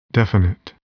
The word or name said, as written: definite